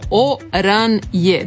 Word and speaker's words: oranje: Nizozemska riječ oranje, narančasto, izgovara se otprilike kao oranje. Dakle, izgovor se dijeli na 3 dijela. O, čisto, kratko, o, kao u hrvatskoj riječi oko. Ran, blago zakotrljano r, nakon kojeg slijedi čisto a, kao u riječi rana.